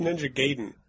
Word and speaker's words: Ninja Gaiden: It's pronounced Ninja Gaiden. – Ninja Gaiden.